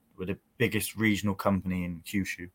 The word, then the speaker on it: Kyushu: So the company is called Kyushu Pro-Wrestling.